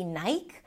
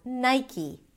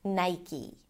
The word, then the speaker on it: Nike: Also, the spelling is deceiving because the E at the end is usually silent. And therefore people assume that it might be Nike, but in fact it's pronounced as Nike. Nai, I as in my, and then E as in C, Nike.